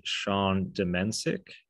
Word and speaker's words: Sean Domencic: Today, we're going to talk with Sean Demensik.